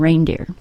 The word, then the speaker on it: reindeer: Other examples are the number 8 and the word way, spelled W-E-I-G-H. The word reindeer, however, is not phonetic. It's one of those words you just need to know how to pronounce.